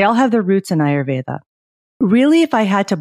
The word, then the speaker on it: Ayurveda: And for our audience, can you tell us what is Ayurveda? And correct me if I pronounced mm-hmm. it wrong. – No, you said it exactly right. So Ayurveda is the healing tradition that originated in India.